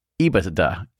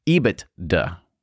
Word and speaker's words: EBITDA: He said, first, hey guys, it's pronounced EBITDA, EBITDA. EBITDA.